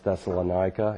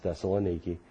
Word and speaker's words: Thessaloniki: We were able to witness in Thessaloniki, or what Americans mispronounce Thessalonica. It's Thessaloniki. Corinth, we were able to do it there.